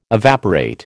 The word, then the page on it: evaporate: American English